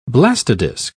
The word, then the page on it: blastodisc: British English